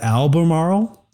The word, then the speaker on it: Albemarle: Well, I want to talk about an interesting company in the lithium business called Albemarle. – How do you pronounce that? Albemarle? – Albemarle.